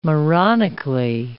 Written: moronically

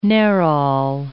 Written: nerol

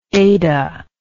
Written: Adah

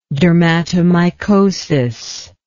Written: dermatomycosis

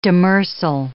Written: demersal

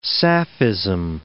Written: sapphism

